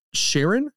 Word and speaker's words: Charon: Pronunciation, Charon? – Yes. Yes.